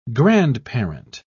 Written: grandparent

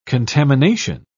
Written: contamination

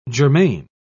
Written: germane

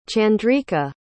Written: chandrika

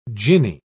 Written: ginny

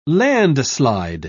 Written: landslide

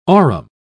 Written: aurum

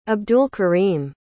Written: abdulkareem

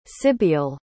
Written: sibeal

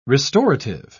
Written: restorative

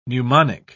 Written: pneumonic

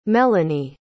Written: melany

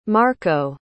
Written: marko

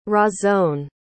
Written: Razon

